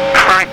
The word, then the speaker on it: Frank: Frank, that's easy. I don't have to spell it. – Okay. – Huh? – F-R-A-N-K.